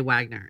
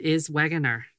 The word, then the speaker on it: Wegener: It sounds like you're supposed to say Wagner and you're saying Wegener in some weird accent.